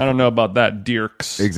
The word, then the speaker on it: Dierks: It's Dierks Bentley. He pronounces it Dirks, but I'm not gonna do him that service. – Okay, so his name is he spells it Dierks like Dirks with an IE, right?